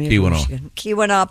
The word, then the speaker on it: Keweenaw: How do you say that, Tom? – Keweenaw Peninsula.